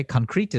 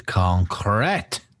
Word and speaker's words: concrete: Concrete is not how you say that word. – Yeah, it's concrete. – That's right. – Concrete. – I just put too much emphasis on the second.